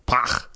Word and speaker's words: Pagh: And this is the Pagh. Pagh? Pagh. – Pagh. It's a, you spell it the same way as gach, but with a P.